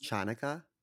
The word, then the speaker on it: Chanukkah: Today is, I believe, day four of Hanukkah, and I think it's pronounced Chanukkah. Chanukkah? – That's right. Sorry, I apologize. You are correct. It is Chanukkah.